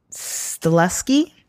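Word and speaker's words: Stahelski: Directed by Chad Stileski. – Mm-hmm. – Might have mispronounced that, pardon. – I think you got it.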